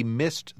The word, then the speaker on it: missed: We missed the bus. Missed. – Note que aqui o som das letras E, D finais mudaram um pouco. Depois de letras duplas, o som D se pronuncia T.